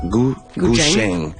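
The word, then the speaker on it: Gu >> Zheng (古筝): Et il y a énormément de Gu Zheng. Ça se prononce Gu Zheng. – Ouais, Gu Zheng. C'est plus un CH, un TCH, mettons.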